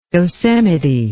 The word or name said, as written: Yosemite